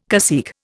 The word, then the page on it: cacique: Indian